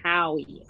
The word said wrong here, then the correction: Howe: I don't know if it's spelled Howie or How, but H-O-W-E. – Yeah, it's How. – Yeah, it's How. – How? – Yeah, okay. It's How over there, yeah.